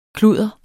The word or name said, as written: kludder